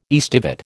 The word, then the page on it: aestivate: American English